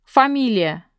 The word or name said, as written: family name